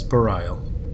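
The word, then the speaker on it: Bareil: Do I always say that name wrong? Is it Barail? – I think it's Barail.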